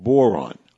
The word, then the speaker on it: Boiron: And one of the companies that have been doing this for, I guess it's somewhere around 80 years, is a company called Boron. And Boron, it's spelled B-O-I-R-O-N, has been doing homeopathy, again, for over 80 years.